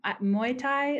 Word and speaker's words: Muay Thai: So cool that you got your fiance into Muay Thai.